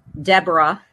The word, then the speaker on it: Deborah: my last name is spelled O-R-O-N-Z-I-O, first name Deborah, D-E-B-O-R-A-H, and I don't believe there are any other Deborah Aranzio persons out there on LinkedIn.